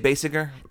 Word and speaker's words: Basinger: Is it Basinger or Basinger? – I would say Basinger.